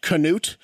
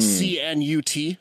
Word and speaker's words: Cnut: I guess it's technically pronounced Canute, but it's spelled C-N-U-T, so that's C-Nut to me.